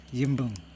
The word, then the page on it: jimbuN: Yokoim